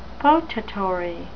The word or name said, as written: potatory